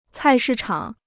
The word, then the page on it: 菜市场: cài shì chǎng